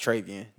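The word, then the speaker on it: Treyvian: No, it's Travian. That's how you say the whole thing. – I'm surprised you said that. – It's T-R-E-Y-V-I-A-N.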